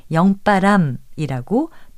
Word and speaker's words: 영바람: 지금 들으신 것처럼 이 표현은 영바람이라고 된소리로 발음합니다. 또 몹시 지친 때에 숨이 차서 숨결이 고르지 못하고 몸이 떨리는 현상을 속바람이라고 합니다.